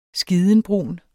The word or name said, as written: skidenbrun